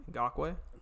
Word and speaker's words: Ngakoue: Ngakoue. – Ngakoue, Nigerian prince.